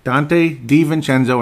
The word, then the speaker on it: Dante DiVincenzo: I thought I knew how to pronounce it, and I didn't pronounce it right. Dante DiVincenzo, and he went to the Milwaukee Bucks.